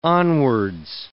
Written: onwards

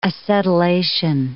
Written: acetylation

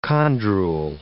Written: chondrule

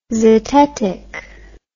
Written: zetetic

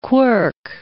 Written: quirk

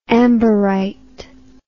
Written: amberite

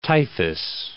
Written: typhus

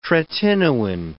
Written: tretinoin

